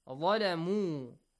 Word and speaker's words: ظلموا: ظَلَمُوا ظَ heavy letter ظَلَمُوا an U sound, right? A long vowel sound قَوْلًا قَوْلًا.